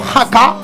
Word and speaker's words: haka: El tema nos llega de la mano de Arcase Records, lleva por título Haka, no es Paka, solo la Haka con H, la costumbre del inglés que pronunciamos la H, no Haka, sería Haka.